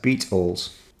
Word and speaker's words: Beatals: spelt B-E-A-T-A-L-S, and pronounced as Beatles.